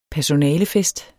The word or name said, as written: personalefest